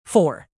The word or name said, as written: fore